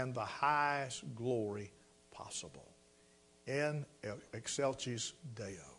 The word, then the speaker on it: in excelsis Deo: Gloria in excelsis Deo, or in excelsis Deo is how it's pronounced.